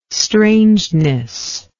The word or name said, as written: strangeness